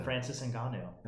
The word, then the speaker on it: Francis Ngannou: Tyson Fury versus, how do you say that? – Francis Ngannou. – Ngannou.